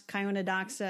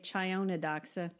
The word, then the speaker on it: Chionodoxa: Well, 1,500 of them were crocuses and what's Gloria the Snow, which I pronounce Chionodoxa, and I think you say Chionodoxa.